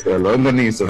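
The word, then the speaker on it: longaniza: We're in Brooklyn, where brisket and lasagna. Am I saying that right? Help me, man. – Longanisa, Freddie. – Oh, see, I got it all messed up.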